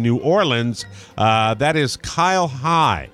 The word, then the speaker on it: Hey: It's Kyle High. I just stop on names like that, that you pronounce it high and it's spelled H-E-Y.